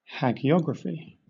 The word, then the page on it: hagiography: British English